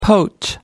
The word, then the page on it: poach: American English